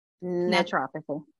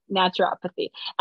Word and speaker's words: naturopathy: It's a little, yeah, it's like nature and then pathy put together, naturopathy.